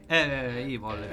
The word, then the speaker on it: Evil: quindi No Evil, quindi detto Evil anche. – Evil, certo. – Le pronunce sono importanti.